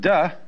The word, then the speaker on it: Te: And they could have spelled it D-A-O or D-O-W, but they didn't want the laity to be initiated. The next word is D, because again, there's no apostrophe after the T, so it's pronounced D.